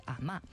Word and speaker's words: 阿嬷: 在这儿要做一个小小的解释啊，我们在这儿说了阿嬷，这是普通话这个字的读音，但是电影里呢，大家会听到潮汕话说的是类似于阿嬷，所以后面大家听到的很多采访当中，大家也是用了这个读音。